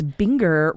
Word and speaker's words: Binger: It's Binger reminds with finger, yes. Rhymes with finger.